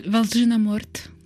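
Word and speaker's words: Valzhyna Mort: Valzina Morte.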